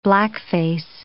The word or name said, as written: blackface